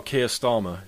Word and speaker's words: Keir Starmer: Ken Starnum or something? – Oh, Keir Starmer, yeah.